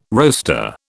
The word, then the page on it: roaster: British English